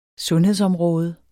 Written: sundhedsområde

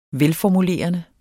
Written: velformulerende

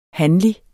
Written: hanlig

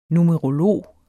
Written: numerolog

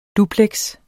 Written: dupleks

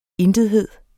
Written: intethed

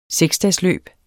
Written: seksdagesløb

